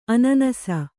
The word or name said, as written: ಅನಾನಸ